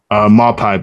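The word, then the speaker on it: Maupay: Yeah, they're so Neil Moody, he's leading the team in goals, I think, right? Is that how you say his name? – Maupai, but yeah, he is leading the team in goals. Maupai.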